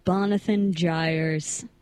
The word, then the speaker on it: Bonathan Gyres: This song is called Bonathan Gyres. – Bonathan Gyres? – Yes. – Please spell that for me. – like Jonathan Byers from Stranger Things, but you switched the B and the J.